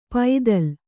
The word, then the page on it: ಪೈತ್ತಲ: paittala